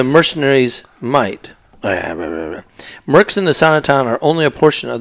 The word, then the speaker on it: Asana: Mercs in Azintown are only a portion of, or maybe it's Asana Town. I think it's Asana Town. Asana Town. – How do you spell it? – A-S-A-N-A Town.